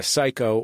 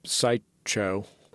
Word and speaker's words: Psycho: I want to play a clip from your most recent stand-up special, which is called Psycho or Psycho. Is there a correct pronunciation? – Well, the full title is There's No I in Team, But There Is a Cho in Psycho. So actually both are correct.